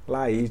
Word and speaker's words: Lay's: L-A-Y-S, Laís, né? É assim que se pronuncia, né?